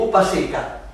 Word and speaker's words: Upasika: Upasika. U-P-A-S-I-K-A.